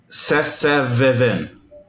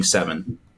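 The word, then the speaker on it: Se7en: Man, I finally got around to watching David Fincher's Sessevivin. I don't. It's for the longest time I was trying to figure out. I'm like, how do you say that? No, I finally got around to watching Seven.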